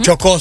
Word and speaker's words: Chocó: No se pronuncia, Chocó, es que se dice. – Uh-huh. Chocó, sí, con Chocó. Acento en la O.